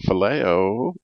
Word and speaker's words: phileo: Today, we're talking phileo. Am I saying that right, Al? – You're saying it right.